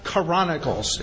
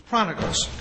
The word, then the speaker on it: Chronicles: I have a friend of mine that makes Chronicles, instead of a three-syllable word, he makes it a four-syllable word. It's only three, but it's not Chronicles, it's Chronicles.